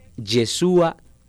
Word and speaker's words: Yeshúa: Se llama Yeshúa TV.